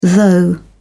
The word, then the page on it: though: British English